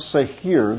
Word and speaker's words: Seir: Even in their disobedience, and they were relegated to going around Mount Seir, that's how it's literally pronounced.